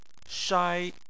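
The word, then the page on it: shai: tone 1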